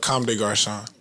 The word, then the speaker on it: Comme des Garçons: Comme des Garçons. – Yeah, that's how you say it.